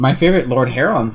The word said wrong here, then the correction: Lord Huron: song. Meet me in the devil worshipper woods tonight. – Yeah, Lord Huron. – Whatever, I don't know how to say his name. Lord Heron, Lord Huron.